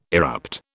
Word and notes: erupt: British English

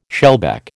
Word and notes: shellback: American English

